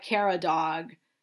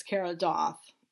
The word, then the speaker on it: Caradog: His first name is Caradog. So it looks like Kara Dog, but I believe it's Kara Doth.